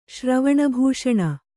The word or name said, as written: ಶ್ರವಣಭೂಷಣ